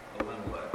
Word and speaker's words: Elimelech: The man's name was Elimelech. Am Am I saying that right? – Close enough.